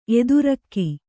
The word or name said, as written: ಎದುರಕ್ಕಿ